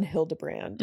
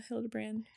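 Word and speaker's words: Hilderbrand: No, if you like Ellen Hildebrand. – Mm-hmm. – Is that how you say it? – Yeah, I think feel so. Like Hildebrand.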